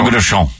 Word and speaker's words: Fogo de Chão: And we went to, I'll say it wrong. Fogo de Chão. – You went to Fogo de Chão. What is it? Is it Fogo de Chão? I think it's Chão.